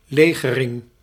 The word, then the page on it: alloy: American English; [ˈælɔɪ]